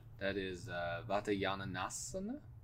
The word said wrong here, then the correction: Vatayanasana: Vatayanasana. Add an extra syllable there.